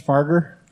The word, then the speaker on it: Fargher: and your last name is Farger? – That's correct.